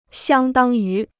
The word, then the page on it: 相当于: xiāng dāng yú